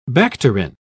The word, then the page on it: bacterin: British English